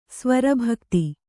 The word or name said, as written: ಸ್ವರಭಕ್ತಿ